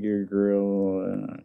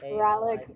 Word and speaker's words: Uralic: It's an Uralic language. – Is that how you pronounce it? Yeah, I would always say like your girl. Yeah, I wasn't sure. – Uralic.